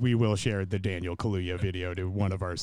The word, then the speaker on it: Kaluuya: and perhaps Daniel Kaluuya.